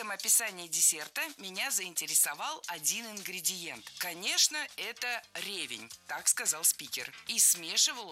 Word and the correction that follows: ревень: Начальная форма не ревень, а ревень. Ударение в этом слове падает на второй слог.